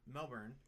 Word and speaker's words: Melbourne: A few years ago, I moved into a one-bedroom apartment in Melbourne, Australia. I said that right? – Mm-hmm.